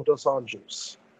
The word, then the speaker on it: dos Anjos: Dos Anjos.